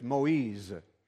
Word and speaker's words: Moïse: And then I realized that the pronunciation for Moses in English is different from the pronunciation in French. In French, it's Moïse.